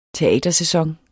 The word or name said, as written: teatersæson